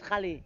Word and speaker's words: Gallie: And my surname is Khali. G-A-L-L-I-E.